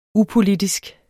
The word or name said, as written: UPOLITISK